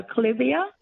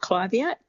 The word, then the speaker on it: clivia: Is it clivia or clivia, Angie? – I call it a clivia. – Yep. But what's the question? Then Ange can tell us how she pronounces it. – Yeah, I was just wondering about its sun requirements and its water requirements. – Yes. So first of all, I pronounce it clivia, but it is a tomato.